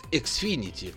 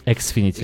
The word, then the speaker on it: Xfinity: Я тебе скажу больше, что если мы, мы обратим внимание на точное произношение, оно называется Xfinity. – Xfinity.